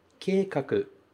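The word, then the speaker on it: keikaku (計画): E ela se pronuncia keikaku. Keikaku.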